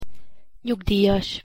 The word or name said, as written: nyugdijas